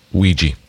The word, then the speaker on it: Ouija: Pronounced Ouija. – Yeah? – Yep. – Okay. Ouija board it is.